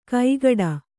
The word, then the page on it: ಕೈಗಾಡ: kaigāḍa